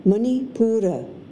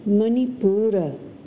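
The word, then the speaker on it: Manipura (मणिपूर): The solar plexus chakra in Sanskrit is pronounced Manipura, Manipura.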